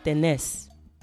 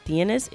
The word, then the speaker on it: tenés: So instead of tienes, it's vos tenés. So tú tienes, vos tenés.